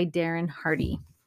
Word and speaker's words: Darren Hardy: I want to share this story from the book, The Compound Effect by Darren Hardy.